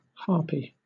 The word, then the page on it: harpy: British English